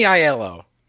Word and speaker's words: Aiello: Danny Aiello.